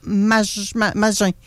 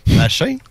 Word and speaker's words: Magin: Il s'agit de Alex Manawel et Jonathan Magin. J'espère que je prononce bien, désolé. – Machin? – Machin. – T'étais à une Magin. M-A-G-I-N.